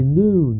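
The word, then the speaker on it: noon (νῦν): The noon, that's how it's pronounced.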